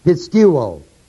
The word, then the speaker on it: pisteuo: It comes from the root word pisteuo, P-I-S-T-E-U, long O.